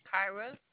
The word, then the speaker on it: Kyra: Kyra.